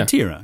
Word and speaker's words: Retiro: I don't know if I said that right. – Oh yeah, but it sounds like it's probably right. Yeah. Retiro. Yeah. Yeah.